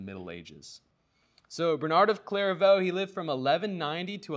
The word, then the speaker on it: Clairvaux: A different Frenchman, and his name was Bernard of Clairvaux, which might be one of my most favorite words, Clairvaux. Yes, everybody loves a silent X.